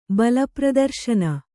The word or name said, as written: ಬಲಪ್ರದರ್ಶನ